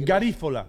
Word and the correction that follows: Garífuna: Garífuna. – Garífunas.